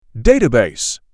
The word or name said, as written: Database